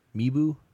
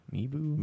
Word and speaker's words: Mibew: It's like Mibu, M-I-B-E-W, Mibu. – Mibu.